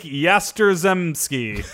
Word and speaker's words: Yastrzemski: Number 11, Yaz, which is Mike Yastrzemski. – Yastrzemski.